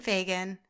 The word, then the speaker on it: Fagan: So if you guys don't follow Kate, like I highly encourage you to check Kate Fagan.